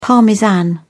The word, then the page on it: parmesan: British English